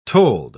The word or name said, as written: told